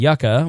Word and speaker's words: yucca: Beans and yucca, which is like a potato, dispersion has nearly doubled to compensate for this. So a lot of beans and a lot of is it yucca?